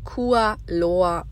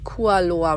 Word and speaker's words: Kualoa: Kua Loa Ranch. So, jetzt haben wir es. Also, die Kua Loa Ranch.